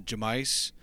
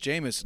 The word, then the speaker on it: Jameis: I kept calling him Jamice, and Pete Peter Pete kept telling me like like it's pronounced Jameis, and I which is refused.